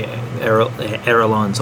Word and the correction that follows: ailerons: Aerolons. How do you say it? – Ailerons. One term I know.